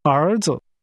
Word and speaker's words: 儿子: 儿子。 – Sons is pronounced with rising and falling rising tones.